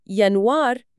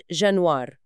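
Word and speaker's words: Januar: فكلمة يانوار هي يانوار مش جانوار.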